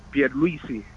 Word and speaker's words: Pierluisi: Pierluisi.